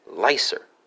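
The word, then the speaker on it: læser: That's going to be Lego, spelled L-E-G-G-O. And for Norwegian, Swedish, and Danish friends, you have Lesser, Lassar, and Leiser, respectively, spelled L-E-S-E-R, L-A-S-A-R, and L-A-E-S-E-R. Careful that A-E in the Danish version is a diphthong, A-E diphthong.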